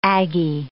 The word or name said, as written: aggie